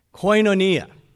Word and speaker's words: koinonia: K-O-I-N-O-N-I-A. K-O-I-N-O-N-I-A. How do you pronounce it? It's kind of like saying Mamma Mia. Koinonia. You got that? Say it with me. Koinonia. Say it like in Italian.